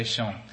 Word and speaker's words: shone: I should have asked one of these teachers, how do you pronounce S-H-O-N-E here in this part of the world? S-H-O-N-E. Sean? Okay, I'll try to say Sean.